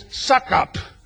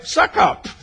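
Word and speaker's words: Succop: It was Suckup. Now, it's spelled S-U-C-C-U-P, but when you say it, it sounds like Suckup.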